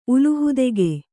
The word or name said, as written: ಉಲುಹುದೆಗೆ